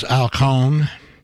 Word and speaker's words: Cohn: That was Al Cohn, rhymes with saxophone,